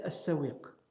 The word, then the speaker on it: Sawiq: Yani, euh, à chaque fois qu'on a parlé de Razat Sawir, je disais Sawir, La, prononciation, euh, la bonne prononciation, c'est As-Sawiq.